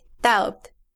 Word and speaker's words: doubt: In English, you would say doubt. Doubt. – That's right. Doubt. Doubt. D O U B T. There is a silent B in the word, una B muda, doubt.